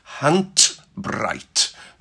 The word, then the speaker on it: Handbreit: Hand breit. Make sure that you put a T, pronounce the D as a T on Hand breit.